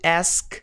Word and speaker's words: asked: No passado, asked, asked, asked, asked. Seria algo como isso aqui. Asked. Asked. Meio estranho, mas essa é a pronúncia, ok? Então, já se acostume com a pronúncia correta. Não é asked.